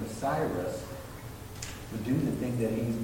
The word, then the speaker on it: Cyrus: We're running out of time, but I love the look up King Cyrus. Am I saying that right? Cyrus, yeah.